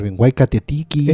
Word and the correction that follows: Waititi: Eh, Tetiti, taika, taika, Waititi. Taika Waititi, que es el de What.